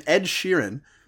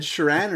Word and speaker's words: Sheeran: Michelle Obama, LeBron James, and Ed Sheeran. Did I say that right? Ed Sheeran or Sheeran? – I think you got it right.